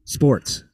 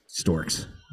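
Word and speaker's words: Sztorc: Sports. – Sports. – Sports. Paul Sports. Nah, I think okay. It's Storks. It's not Sports.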